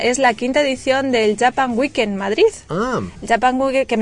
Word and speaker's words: Japan: está Japan, bien pronunciado, ya sabéis, Japan.